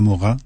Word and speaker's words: Morin: Y bueno, también la presencia de una serie de acontecimientos tristes, pero al mismo tiempo también importantes, como la muerte de Edgar Morin, se pronuncia así, porque nos lo leímos muy jóvenes, se escribe Edgar Morin,